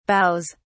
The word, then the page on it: boughs: British English